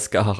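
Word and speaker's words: Scáthach: Und es ist natürlich auch hier irische Mythologie und darum, das wird halt Skahach ausgesprochen. Aber okay.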